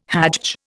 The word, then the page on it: hajj: Indian